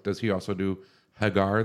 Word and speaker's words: Hagar: I would say Hagar because it's, yeah, said, it's spelled the same as Sammy Hagar.